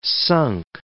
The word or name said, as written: sunk